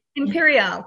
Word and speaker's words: Imperiale: Imperial, is that how we like to pronounce it? – Imperial, correct.